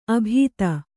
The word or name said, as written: ಅಬುದಿ